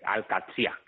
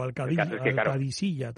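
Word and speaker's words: Al-Qadsiah: Brighton y Al-Qadsiyah, creo que es el equipo de Kim Fernández, no sé si lo he dicho bien. – Sí, o Al-Qadisiyah también se escribe, sí.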